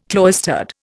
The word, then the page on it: cloistered: Indian